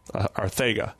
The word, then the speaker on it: Arcega: You say Arcega, instead of the S, you need to make it a TH sound. – Arcega.